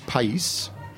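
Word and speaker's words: País: The grape is pais. – Spell it. – P-A-I-S. – Like country. Pais. – Like country, right.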